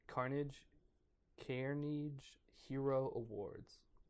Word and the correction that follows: Carnegie: Carnegie? I think it is Carnegie. Which I realized after saying Carnage, and then I'm like, is this going to be Carnegie?